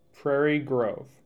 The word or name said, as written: Prairie Grove